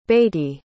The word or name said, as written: beatty